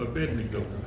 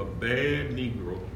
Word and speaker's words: Abednego: he changed his name to Abednego. Some have, chose to pronounce that differently. They said they changed his name, Azariah, to a bad Negro.